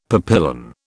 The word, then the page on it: papillon: British English